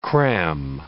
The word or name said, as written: cram